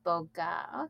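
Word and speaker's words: Bogue: Is that Bogucito, Mississippi? My pronunciation sucks on so many things. Close. Okay. Well, I'm like Utica, Ohio, and it's like farmland. So, oh, Boga, pronounced Boga.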